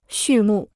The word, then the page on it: 畜牧: xù mù